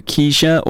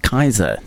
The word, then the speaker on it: Kiesza: Most people call her Keisha or Keiza. It's actually pronounced Kaiser. There was an interview with her where she finally broke out and said, "It's so nice that people are getting my name correctly." It is spelled K-I-E-S-Z-A, so you do tend to go Keiza, but it's Kaiser.